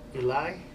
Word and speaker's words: Eligh: He formed Three Melancholy Gypsies with fellow Alexander Hamilton High School classmate Scarab and Eli. I was saying it right. – What's that? – E-L-I-G-H.